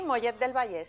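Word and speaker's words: Mollet del Vallès: De Mollet del Vallés, ¿se dice así correctamente? – Sí, sí, Mollet del Vallés, sí.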